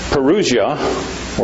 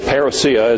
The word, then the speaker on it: parousia: The parousia, or parousia as it's variously called, both pronunciations are correct, parousia or parousia, meaning the coming of the Lord.